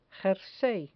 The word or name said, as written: jersey